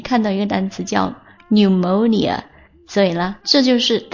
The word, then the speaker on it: pneumonia: 那急性肺炎叫做 pneumonia， p 也是一个废物不发音 pneumonia， p 和 e 都不发音 pneumonia， pneumonia，急性肺炎。